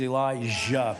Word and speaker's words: Elijah: Would you say that please, Elijah? Put the emphasis on the Jah, okay?